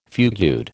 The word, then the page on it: fugued: American English